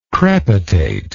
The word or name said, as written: crepitate